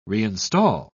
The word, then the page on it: reinstall: British English